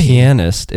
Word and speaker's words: pianist: You said it on one of your Instagram feeds, and I thought, pianist. I don't think that's how you say it. It's really? – Not pianist, it's pianist.